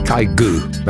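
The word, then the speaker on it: Kygo: Been battling with that guy's pronunciation for like 10 years now. Kaigu. It's Kaigu. That's the Norwegian pronunciation.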